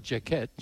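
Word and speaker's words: Jacquet: Jacket, not Jacket, Jacket.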